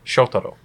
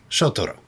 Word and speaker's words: Shotaro: Shotaro. – Shotaro.